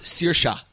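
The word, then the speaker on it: Saoirse: It's actually pronounced Searsha. – That's Searsha. Okay, we'll give it to you.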